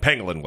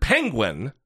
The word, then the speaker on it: pangolin: I had no idea what a fucking pangolin was. When I first heard it, I heard penguin, and it's not penguin, it's pangolin, which...